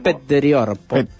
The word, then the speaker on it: Petteri Orpo: Petteri Orpo.